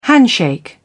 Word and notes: handshake: British English

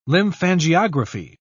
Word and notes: lymphangiography: British English

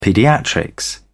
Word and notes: paediatrics: British English

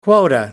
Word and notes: quota: American English

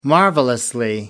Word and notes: marvelous: American English

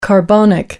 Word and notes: carbonic: American English

